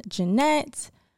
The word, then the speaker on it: Jeannette: And also you can find me on all streaming platforms at Autumn Jeannette, my first name, and then Jeannette is spelled JeAnnette.